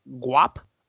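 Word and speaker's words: Gwap: This name is, okay, it's spelled G-W-A-P. I'm going to go ahead and say it. Guap underscore Kevin.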